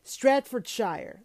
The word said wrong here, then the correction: Staffordshire: Ugh, God, I fucked that up. Staffordshire, Fenton, Staffordshire. Anyways,